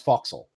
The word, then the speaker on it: forecastle: Boatswain is the spelling. The pronunciation is bosun. Sort of like how the forecastle is actually pronounced foxhole.